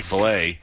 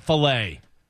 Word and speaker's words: Flay: Speaking of stupid chefs, Mike Butta, the chef from Chain is beat Bobby Flay. – Flay! – Bobby Flay. – Bobby Flay. – Flay. He's not a piece of meat. He's Bobby Flay, not Filet.